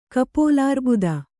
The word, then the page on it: ಕಪೋಲಾರ್ಬುದ: kannada